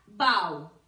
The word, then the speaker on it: bow: Es decir, so, so y bow riman. Este bow, que significa lazo, pero no rima con esta otra palabra que se escribe igual, pero se pronuncia bow. Veámoslo. When you meet the queen, you bow. Cuando encuentras con la reina, le haces una reverencia. You bow which again must rhyme with how. Bow, how. La H, acordaos, ¿eh?